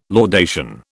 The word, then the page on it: laudation: British English